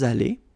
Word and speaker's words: allés: Nous sommes allés. You don't pronounce it. Okay, but you write it.